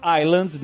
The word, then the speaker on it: Island: A gente, a gente fala Island, né, porque tem esse S, mas esse S é mudo, né?